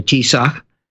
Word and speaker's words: Taoiseach: Also, a very good meeting today between Donald Trump and the Prime Minister of Ireland, the Taoiseach of Ireland. That's how they say in Irish, the Prime Minister, the Taoiseach, and pronounced about the same way in Scottish Gaelic.